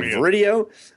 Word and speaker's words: VRidio: I don't know how you're supposed to pronounce that, but video with an R in it. – Viridio. – Yeah, Viridio.